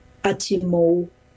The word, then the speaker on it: Atimo: And so Dachimon, Kitachimon, Atimo, with a kind of W sound at the end of it.